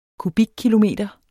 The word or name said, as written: kubikkilometer